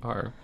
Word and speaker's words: Worcester: If people say Worcester, they just say Worcester. It's just the way it is. – Spell it Worcester then. Wooster.